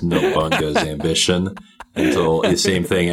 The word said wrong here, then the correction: Nobunaga: A game store guy was like, "Oh, you're buying Nobunaga?" So I'm like, "That's not how you say it." And then I looked at him like, "Oh, he's right."